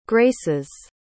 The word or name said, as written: graces